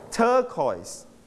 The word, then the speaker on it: turquoise: your turn 3 turquoise stress on the first syllable turquoise Turquoise.